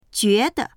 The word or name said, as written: jue2de